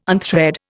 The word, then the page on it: unthread: Indian